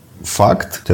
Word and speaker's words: FUCT: Верховный суд рассмотрел заявление Эрика Брюнетти, который является известным на территории Соединенных Штатов дизайнером и выпускает одежду под брендом Факт, F-U-C-T пишется.